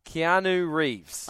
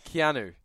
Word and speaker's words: Keanu Reeves: Keanu Reeves. – Oh, yes. – Did I say it right? – You did, Keanu. – But I always have to slow down. Keanu.